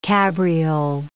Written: cabriole

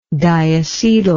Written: diacetyl

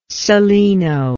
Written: celaeno

